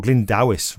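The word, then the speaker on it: Glyn Dewis: Appreciate Dewis, or Glyndowis rather. Let's get the Welsh way uh, yeah.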